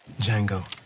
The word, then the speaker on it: Django: "Django. D-J-A-N-G-O. The D is silent."